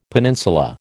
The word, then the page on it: peninsula: American English